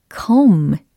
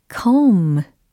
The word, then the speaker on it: comb: 자, comb, comb. 요겁니다. 오, 부가 어디 갔지? 라고 예, 질문하실 수도 있는데, 이렇게 끝에, 어, 지금 비가 철자로는 있지만, 발음상으로는, 어, 절대 되지 않습니다. 예, 무성이 되는 거예요. 그래서, 어, 묵음 처리를 하시면 되겠습니다.